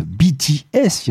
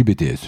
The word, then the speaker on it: BTS: BTS. Il y en a qui aiment bien prononcer comme ça. Moi, je dis BTS. Je suis français.